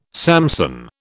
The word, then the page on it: Samson: British English